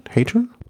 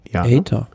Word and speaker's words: Hayter: Vielleicht kurz auch zur Einordnung, Gesamtklassement, David Godu vor Brandon McNulty vor Ethan Hayter. Ist das richtig ausgesprochen? Hayter? – Hayter. – Hayter. Ja.